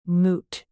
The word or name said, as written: moot